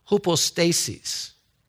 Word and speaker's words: hypostasis: Now, that word substance, faith is the substance, that word substance comes from the Greek word hypostasis. I said it right.